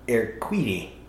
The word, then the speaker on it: Urquidi: So there was one person named Victoria, and it's pronounced, it's spelled U-R-Q-U-I-D-I, but I believe it said Victoria Urquidy.